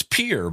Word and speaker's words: Pierre: I grew up in South Dakota where the capital city is Pierre, and it's spelled like the French Pierre, but no South Dakota cowboy is going to go around saying Pierre. So it's Pierre, but I'll bet you this gentleman's name is Pierre, unless he's from Pierre, South Dakota.